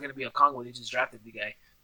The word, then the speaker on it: Okongwu: Okongwu? Is that how you say it? – Yeah. – Okongwu? – Yeah, Okongwu.